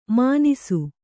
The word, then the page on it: ಮಾನಿಸು: mānisu